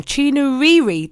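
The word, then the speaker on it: Chinouriri: And of course, I'm going to be bringing you tunes from the likes of Rachel Chinaruru. No, I said that wrong. Chinuriri. There you go.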